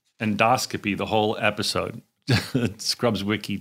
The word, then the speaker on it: endoscopy: Endoscopy, if you're curious, is the correct way to say it.